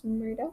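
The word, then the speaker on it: Murdaugh: Okay, so I'm doing the case on where Alex Murda, is that how you pronounce it? – Yeah.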